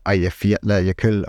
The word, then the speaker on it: Eyjafjallajökull: But I think I would be surprised if it was on the scale of the Eyjafjallajökull eruption, which was the one that caused all the havoc. – Come on, props for that. – Yeah, wow. – Their pronunciation here. Well done.